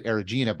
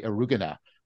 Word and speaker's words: Eriugena: I was brought up to pronounce it Erigena, but I'm hear, hearing people say Eriugena.